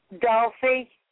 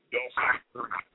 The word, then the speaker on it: Dulcia: Dolphy. – Dorothy? – Dolphy, D-U-L-C-I-A. – Okay, D-U-L-C-I-A, Dolphy.